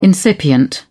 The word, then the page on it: incipient: British English